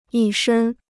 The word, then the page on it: 一身: yī shēn